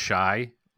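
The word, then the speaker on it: Chi: So his first name is pronounced Shy, like Chicago.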